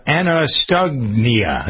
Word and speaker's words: anosognosia: What you have is not Alzheimer's, but anosognosia. Hey, let me see if I can pronounce this. A-N-O-S-O-G-N-O-S-I-A. Anosognosia. That's what it is.